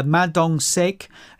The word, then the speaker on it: Ma Dong-sik: Ma Dong-sik, who is a...